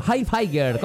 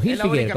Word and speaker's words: Hilfiger: Hilfiger, ¿cómo se dice? – Hilfiger. – O Hilfiger, como se quiera.